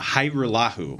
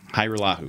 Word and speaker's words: Hajrullahu: It's Lirim uh, Hajrullahu. There you go. Hajrullahu. Hajrullahu. Okay, so the J is silent.